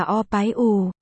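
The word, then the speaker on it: o bái u (二百五): E bài u, đọc là o bái u, nghĩa à 250 nếu dịch nghĩa đen.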